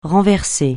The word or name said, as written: renverser